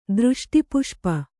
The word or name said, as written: ದೃಷ್ಟಿಪುಷ್ಪ